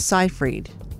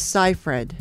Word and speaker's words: Seyfried: It's not Seyfried. – I thought it was Seyfried. – It's Seyfried. – Seyfried.